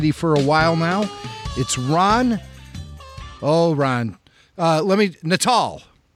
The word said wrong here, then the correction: Nuttall: Close enough. – Help me out. – Nuttall. – Ron, yeah. Nuttall.